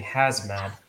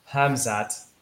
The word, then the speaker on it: Hamzat: Yeah, so I have to, especially with COVID going on, I keep saying Hazmat, but it's not that. I have to really take the time and pronounce his name. It's Hamzat. Hamzat.